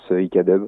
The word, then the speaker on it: Ikadub: Voilà, il n'y a pas de traduction anglaise, du coup, bah, vu qu'on est français, on le prononce Ikadub.